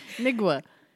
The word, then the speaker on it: Nwigwe: Try that again. – Nwigwe? – Why are you saying way? – Nwigwe. – Nigwu. Yeah, that's closer. – Bitch, you don't know how to say his name. – But it's not Nigwe. Nwigew. That ain't Nigwe.